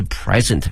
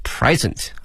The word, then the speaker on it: present: The presents 啊，注意一下这个词 presents 礼物，我们想到礼物啊有两个词，一个是 gift G-I-F-T 可以哈，还有一个呢是 present P-R-E-S-E-N-T 这个词啊，如果表示礼物的话呢它的发音是 present 啊，注意一下它的发音呢，重音在前面啊，present。如果这个词哈、啊、作为动词的话呢，则变成了 present，表示呢展示的意思哈、啊。所以用这个词的时候呢，同学们要加以小心。